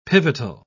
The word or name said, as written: pivotal